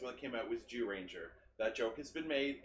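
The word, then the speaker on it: Zyuranger: Yeah, any jokes unless it says Z on the buckle. It's Zoo Ranger. – It says Z. – It's pronounced Jew Ranger.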